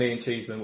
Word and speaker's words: Ian Cheeseman: And Ian Cheeseman, which I think I've nailed the pronunciation of. – Yeah, you're all right.